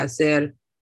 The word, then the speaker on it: hacer: Hace, va a hacer. Mucho calor. – Mucho calor. Muy bien. Okay. Acuérdate, so remember, que la H no se pronuncia.